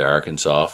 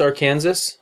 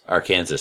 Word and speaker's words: Arkansas: If I make it to Arkansas for Mag 40 with, I believe that's pronounced Arkansas. – Arkansas. Sorry. Arkansas.